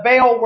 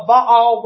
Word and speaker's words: Baal: But in the Hebrew, it's Baal. So are you going to go to the worship service named after Baal, Baal worship?